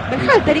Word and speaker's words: Berhalter: Dígalo usted. – Berhalter, ¿y qué dije yo? – No, impecable.